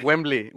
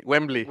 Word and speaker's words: Wembley: ¿Wembley o qué? – Wembley, Wembley.